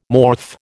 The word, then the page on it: morph: American English